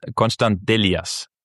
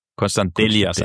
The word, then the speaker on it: Konstantelias: Aber Yannis Konstantelias. Konstantelias, also mit einem E und einem Akzent, so wird es mehr oder weniger ausgesprochen. – Also die Betonung auf dem E, okay.